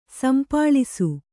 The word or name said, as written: ಸಂಪಾಳಿಸು